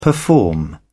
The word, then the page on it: perform: British English